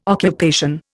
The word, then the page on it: occupation: Indian